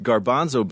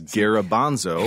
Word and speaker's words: garbanzo: When you mispronounced garbanzo beans as garabanzo.